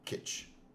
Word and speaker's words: kitsch: It is indeed Kitsch. Mm. Which sounds a lot, which makes more sense now.